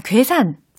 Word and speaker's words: 괴산: And so this article it talks about specifically 고산. 아 괴산.